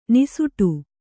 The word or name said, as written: ನಿಸುಟು